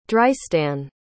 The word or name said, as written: drystan